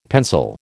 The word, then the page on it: pencil: American English